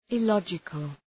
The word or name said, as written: illogical